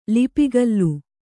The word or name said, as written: ಲಿಪಿಗಲ್ಲು